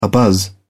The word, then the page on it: abuzz: British English